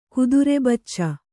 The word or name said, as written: ಕುದುರೆಬಚ್ಚ